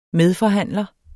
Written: medforhandler

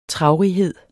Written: traurighed

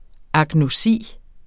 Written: agnosi

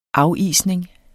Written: afisning

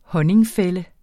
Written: honningfælde

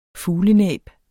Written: fuglenæb

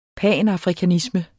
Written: panafrikanisme

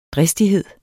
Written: dristighed

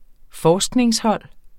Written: forskningshold

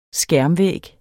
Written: skærmvæg

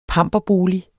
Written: pamperbolig

